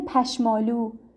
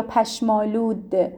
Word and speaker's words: پشمالود: حالا بعضی ها میگن پشمالو، اون ده رو تلفظ نمی کنند، به آلو ربطی نداره، پشم به علاوه آلود.